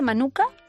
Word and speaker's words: manuka: jengibre y miel de manuka, ¿lo he dicho bien? – Lo has dicho muy bien.